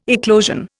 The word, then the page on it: eclosion: Indian